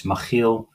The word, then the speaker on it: Makiel: It's what most people say. It's a Dutch name. In Dutch, it's Makiel. I know it's a little bit difficult to pronounce, so how you say it, great.